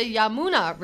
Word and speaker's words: Yamuna: It's the Yamuna River. – Oh, no. – Y-A-M-U-N-A.